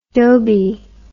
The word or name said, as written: dhobied